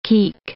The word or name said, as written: keek